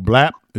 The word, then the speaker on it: BLAPP: It's for those out there listening, it's called the app is called BLAPP. It's spelled BLAPP.